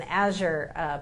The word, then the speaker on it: Azure: I was having dinner with a guy who I know is an Azure partner. And she said Azure. – Did I say it wrong? – No.